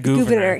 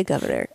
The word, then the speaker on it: Gouverneur: Yeah, but it was Gouverneur. She said Gouverneur. – Yeah, it's spelled like Gouverneur. – Yeah, G-O-U. – Yeah, but I'm going to say Governor. Cause okay, fair enough. Yeah. – I'm not going to say Gouverneur. – Okay.